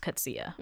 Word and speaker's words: Coetzee: So I think I'm pronouncing it Coetzee, but it's pronounced Coetzee.